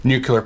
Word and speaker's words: nuclear: Now, before I go into exact what cold fusion is, I'm going to give a brief primer on the whole idea of nuclear power. Cold fusion is supposed to be a form of nuclear power. – Nuclear. I've called you on this before. Nuclear. Say it clearly and you'll say it correctly, as my physics professor used to say.